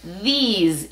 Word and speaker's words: these: Y luego vemos la E y se nos asemeja más al de, pero no. Es these.